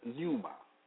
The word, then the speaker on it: pneuma: The Greek word for spirit is pneuma, pronounced pneuma, meaning a current of air, breath or breeze, an angel, a demon or divine, God, Christ spirit, Holy Spirit, ghost, life, spirit, mind.